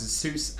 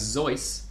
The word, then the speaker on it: Seuss: Alright, you pretentious pelicans out there demanding me to pronounce Seuss as Zeuss. You're wrong, but that's okay, because you're going to learn a little something.